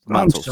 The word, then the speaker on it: Matos: Is that how we say your last name, sir? Matos. – Matos. I'm sorry, Matos.